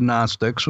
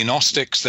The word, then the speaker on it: Gnostics: You mentioned the Gnostics, which is spelled with a G at the beginning for those that have never heard the word.